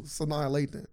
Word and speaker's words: Sanaa Lathan: You just said her name wrong, but it's fine. – Shanae Langton. – Oh, that was better. – Shanae Langton. – You said it wrong again. – How you say it? – The second time was cool. Sanaa Lathan. – Sanaa? – Yes.